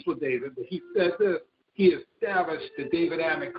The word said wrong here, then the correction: Davidic: covenant. And that means that David, everybody that's sitting upon the throne, the Davidic covenant, sorry, I said it wrong, the Davidic covenant, sorry, I said that wrong.